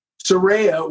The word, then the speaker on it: Saraya: Is it, so how do you say it? Soraya? – Soraya, which Soraya. Was actually Sandy Soraya, great, great singer, but it was actually called Alsace Lorraine before they got their deal.